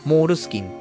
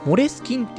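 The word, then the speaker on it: Moleskine: そしたら、こ、日本だけなんだってね、モールスキンっていう風に発音して読んでるのは。現地だと、発音の方はモレスキンっていう風に発音するらしくて、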